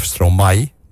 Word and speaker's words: Stromae: Stromae, die kennen we, is de artiestennaam van Paul van Haver, Belgische singer-songwriter van hip-hop en elektronische muziek. De naam Stromae, of Stromae, moet ik eigenlijk zeggen, is wat in het Frans een Fernand wordt genoemd.